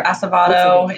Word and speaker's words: Acevedo: It's Acevedo or Acevedo. It depends. Freddie says it kind of differently than I do. I feel like I say Acevedo.